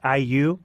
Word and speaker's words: IU: And Sarah, the next track on your playlist is Celebrity by the vocalist, is it pronounced IU, like Indiana University, right? – Yeah. It is.